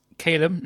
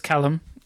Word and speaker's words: Callum: My name is Callum. No, it's not really. It's Callum, which is a very commonly mispronounced name, which Julian was calling me Callum for like a year or two.